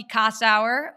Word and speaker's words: Kossower: Very excited to have Chelsea Kossower, who, by the way, we were just laughing because I was self-conscious about the way that I pronounced last name.